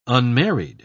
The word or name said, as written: unmarried